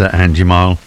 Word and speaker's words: Anjimile: That's Andy Mile, and his name of the artist, and a track called Maker. And that's spelled A-N-J-I-M-I-L-E, as in mile, as in country.